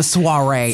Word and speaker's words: soiree: What all is involved in the soiree other than those great drinks? – I mean, and the great food.